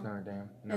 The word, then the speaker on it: Notre Dame: Did I say it wrong? – But no. It's Notre Dame.